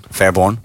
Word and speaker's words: Fairbairn: Fairbairn.